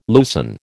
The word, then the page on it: loosen: American English